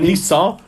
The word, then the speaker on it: Nizza: Eis ist, ich weiß nicht, wie man das, spricht man das? Ist es Nizza? – Also es ist sicher Nizza.